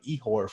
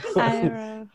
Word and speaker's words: Iroh: And the character analysis is going to be on Ihor from Avatar. I'm probably not pronouncing it right. I've never seen Avatar. – Iroh.